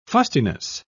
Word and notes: fustiness: British English

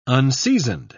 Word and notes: unseasoned: British English

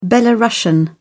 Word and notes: belarusian: British English

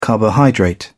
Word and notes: carbohydrate: British English

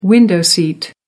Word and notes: window seat: American English

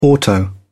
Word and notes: auto: British English

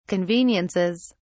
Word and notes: conveniences: British English

